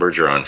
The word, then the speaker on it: Bergeron: No, I was like, I was thinking the host Tom Berenjohn. Berenjohn? Is that how I say his name? – That's Tom Bergeron from Bergeron. That's right.